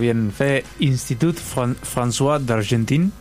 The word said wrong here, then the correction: Institut Français d'Argentine: ¿Lo dije bien? – Francés. – Institut Français d'Argentine.